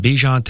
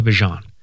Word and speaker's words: Bijan: And we got that at the tail end of last season when Bijan Robinson went from Bijan to Bijan.